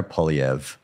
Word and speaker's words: Poilievre: Trudeau is under some pressure now from, I'm going to try and pronounce his name, I get it wrong, Poilievre? – Let's do it a few more times. – Poilievre? How do you say his name? – Pierre Poilievre. – Poilievre, that's it.